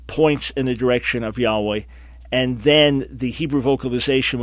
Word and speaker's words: Yahweh: So those would be three arguments that I would say point strongly against Yehovah / Jehovah, and therefore Yahweh remains what we understand to be the best pronunciation.